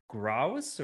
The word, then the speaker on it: grouse: Is it a grouse or a grouse? – Grouse.